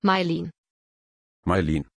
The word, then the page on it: Maileen: German